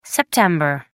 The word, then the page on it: Sept. 11: American English